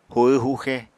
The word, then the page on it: huhw-huxe: apatani